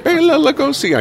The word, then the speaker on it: Bela Lugosi: Bela Lugosi. I mispronounced it the first time.